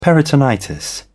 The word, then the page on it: peritonitis: British English